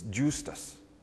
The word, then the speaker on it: Justus: Now, there are those who say Justice is not properly pronouncing this word. It's Justus. But Justice, Justus.